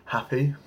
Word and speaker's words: Hapi: There was, now bear with me, I'll try and get the pronunciation right, Hapi, the Nile god created for making land fertile. That's H-A-P-I, not H-A-P-P-Y.